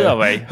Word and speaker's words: Huawei: Ja, är det någon klocka jag verkligen skulle vilja prova så är det just, eh, ja, nu kan jag inte uttala märket men Huawei eller hur fan man säger. – Hu Huawei, Huawei, som vi säger i Skåne.